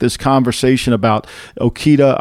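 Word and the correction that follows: Okudah: Yeah, Jeff Okuda from Ohio State. – Okuda.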